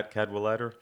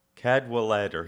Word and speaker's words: Cadwallader: And so, but he kept bad-mouthing Washington, which led to a duel, which is a guy you mentioned a second ago, John Caldwell Otter. I think that's how you say it. – Yeah, Cadwallader. – Yeah. – Cadwallader.